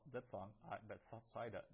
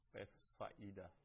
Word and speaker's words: Bethsaida: So that we don't pronounce that as a diphthong. Alpha and iota together would normally be a diphthong, Bethsaida, but we pronounce it Bethsaida.